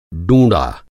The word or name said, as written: डूँड़ा